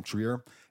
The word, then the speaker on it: Trier: The writer director is Joachim, is it Trier? Yes. That's how you pronounce his last name, Trier.